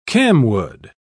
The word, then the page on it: camwood: British English